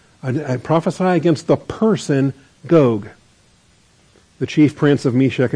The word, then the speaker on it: Gog: All right, word of the Lord came to me saying, Son of man, set your face towards Gog. That's a personal name. Gog or Gog, make it a long O, of the land of Magog, the prince of Rosh, Meshech, and Tubal, or the chief prince of Meshech and Tubal.